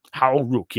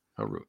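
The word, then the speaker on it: Hau Ruck: So how do you pronounce, is it Hal Rook? – Hal Rook, yep. – Hal Rook.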